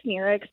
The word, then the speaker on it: Sneerix: I just say Sneerix because that's phonetically what it looks like.